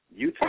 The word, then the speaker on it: Eutaw: Utah, Alabama. – Yes. It's not spelled like the state. It's spelled with an E. It's spelled E-U-T-A-W.